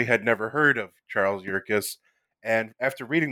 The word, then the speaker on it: Yerkes: But I think one thing that's worth talking about, you had mentioned the name Charles uh, Yerkes. Am I saying that right? Right. Yes, so Charles.